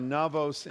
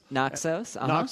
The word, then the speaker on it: Naxos: Instead of Santorini, try Navos in Greece. – Naxos.